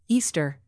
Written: easter

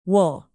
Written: wool

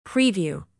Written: preview

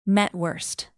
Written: mettwurst

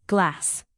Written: glass

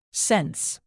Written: sense

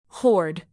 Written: hoard

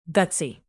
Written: gutsy

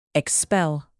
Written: expel

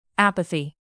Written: apathy

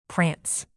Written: prance

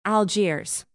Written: algiers

